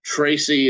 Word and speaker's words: Tracy: Tracy is TRACY.